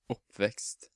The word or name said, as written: Oppvekst